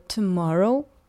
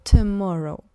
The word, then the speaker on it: tomorrow: Обращаю ваше внимание, что несмотря на то, что в слове tomorrow у нас целых три буквы O, каждая из них произносится по-разному. Первое O скорее совсем не произносится. Она может быть изменена как на звук «ы», так и вообще не звучать. Либо tomorrow, просто без звука, либо tomorrow с так называемым звуком шва.